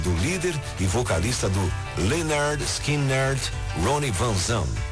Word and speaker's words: Lynyrd Skynyrd: Em seu disco de estreia, lançado em 1973, a banda liderada pelo vocalista Ronnie Van Zandt mostrou ao mundo qual era a pronúncia correta do nome, Lynyrd Skynyrd.